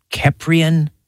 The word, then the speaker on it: Keprian: Yeah, Keprian, you've got it correct. And a lot of people pronounce it wrong. And Egyptologists have even said that I might be pronouncing it wrong. So it's all good. We'll go with Keprian, and that's going to be our common pronunciation tonight.